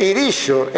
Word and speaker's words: Irixo: Cando isto ocorra, cando os galegos aprendan que as xotas de Irixo e de Bixueses e os xes de Punxín e de Sanxenxo se pronuncian como as CH francesas, teremos dado un grande paso na verdadeira pronunciación.